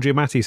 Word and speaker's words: Giamatti: I'm going to say it the way Paul Giamatti says it, which is Giamatti.